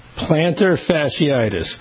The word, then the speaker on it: plantar fasciitis: Plantar fasciitis.